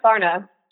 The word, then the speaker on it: Darna: I remember I was walking today and I was like, could it be like a silent D? Like Harna or Daharna? – No, definitely not. Like, honestly, though, I don't even really pronounce my name right. Like, I say Darna, but like, whatever, I'm not even going to try.